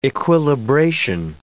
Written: equilibration

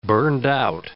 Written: burned-out

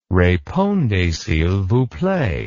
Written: repondez s'il vous plait

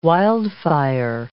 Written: wildfire

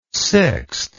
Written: sixte

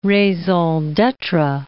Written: raison d'etre

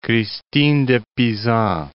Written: Christine de Pisan

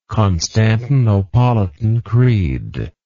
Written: Constantinopolitan Creed

